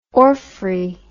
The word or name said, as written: orfray